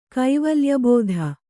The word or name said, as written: ಕೈವಲ್ಯಬೋಧ